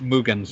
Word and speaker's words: Mugen: I usually pronounce it Mugen Souls. Mugen. – Well, you said you're still like inserting this extra ya sound that isn't in there. – Yeah. – It's Mugen Souls.